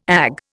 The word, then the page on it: Ag: Indian